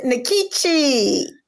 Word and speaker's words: Nkechi: Well, I pronounce it Nkechi.